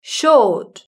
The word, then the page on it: short: British English